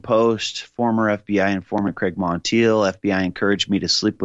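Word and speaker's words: Monteilh: Craig Montiel, Craig Montiel, that's his name.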